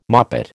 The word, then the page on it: moppet: American English